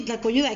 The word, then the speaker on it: tlacoyuda: Hay otra palabra muy curiosa que también existe que, que se llama este tlacoyuda, se, pronuncia así, tlacoyuda.